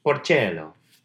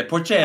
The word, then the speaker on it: Porcello: Oh, right. Porcello. I'm sorry. – Yeah, Porcello. – I mispronounced that name.